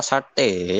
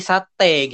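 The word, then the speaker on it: Sarthe: Oh, Sarte, Sarte bukan sarte ntar sarte jadi sate gitu kan di Madura iya sate padang nah sate padang sate Madura gitu sate ayam dibacanya jadi dibaca sart Sart, ya.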